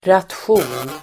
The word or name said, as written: ration